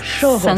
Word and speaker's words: Choros: Alors, comment prononce-t-on correctement ce Choros, Choros 5. Choros numéro 5.